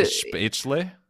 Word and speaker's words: Spätzle: Oh, it's so, spätzle. It's like spätzle. You pronounce the lay at the end.